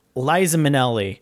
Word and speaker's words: Liza Minnelli: So among those stars, you have Burt Reynolds, James Caan, Liza Minnelli, Liza Minnelli. I almost said it wrong the first time.